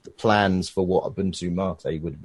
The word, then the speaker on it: Mate: It's a Spanish word. Its origins are in South America. It's a caffeinated tea-like beverage, Mate.